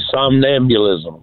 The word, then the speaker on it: somnambulism: The somnambulism question.